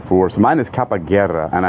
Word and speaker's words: Capoeira: oh my God, I can never pronounce this correctly. – It's a Capoeira. Yeah, Capoeira.